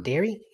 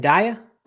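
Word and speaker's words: Daré: Dairy? You mean Daya? – No, dairy. D-A-R-E. – Okay. That's right. It's pronounced dairy. It's spelled mm-hmm. dare, pronounced dairy.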